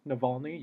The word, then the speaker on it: Navalny: He's more than once voiced support for the leader of the Russian opposition party, Alexei Navalny. Navalny, yes, that's how you say it. I had to look up the phonetic spelling, wanted to be correct.